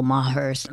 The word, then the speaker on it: Maher: I'd have to see more proof than Bill Maher's. How do you say his Bill Maher. – Maher. Bill Maher. Maher. Yeah, Maher.